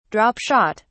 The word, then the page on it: drop shot: British English